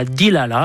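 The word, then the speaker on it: Delilah: Gira, grup Queen, cu sta canzone che lui ha chiamato Delila, ma che lui pronuncia Dilala, appena così, a maniera americanesca, ma io, l'americano, una maestria che mica abbastanza.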